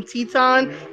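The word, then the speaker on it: Titán: By the way, I don't know why we call him Teton. It's spelled Titan, but because he's Mexican and he's representing CMLL and also representing LIJ, we say Teton. Just please put the accent over the A. It'll be Teton.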